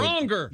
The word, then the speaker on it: Bronger: Now, Matt Bronner, comedian on the road. – Bronger. – You got to nail that. It's okay. – I had it in there subtly. I'm doing the French pronunciation. – No, nope, you're not. Okay. – Matt Bronger.